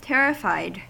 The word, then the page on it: terrified: American English